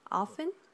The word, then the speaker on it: often: Con esa palabra often, también se puede pronunciar la T si quieres, ¿ok? Tienes la opción en inglés. Tenemos dos pronunciaciones, ¿no? Often, sin la T, y también often. Often. A mí, yo prefiero often, pero otras personas, ellos prefieren often.